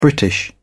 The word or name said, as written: british